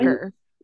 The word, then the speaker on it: Dacre: So Richard Rider, I think, was it, how do you say his first name? Decray Montgomery. – Dacre Montgomery? – Dacre. – Yeah, Dacre Montgomery.